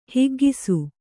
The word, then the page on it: ಹಿಗ್ಗಿಸು: higgisu